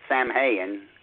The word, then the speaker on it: Samhain: But, you see, Samhain is spelled, remember, this is a Gaelic word. It's S-A-M-H-A-I-N. Samhain. Some people pronounce it Samhain. Well, no, that's not wrong. This is Gaelic. Mm-hmm. It's pronounced Samhain.